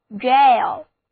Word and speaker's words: gaol: 监狱就是 prison，它的同义词就是 jail，在英国英语中拼写作 G-A-O-L，读音是相同的，都是指监狱 jail。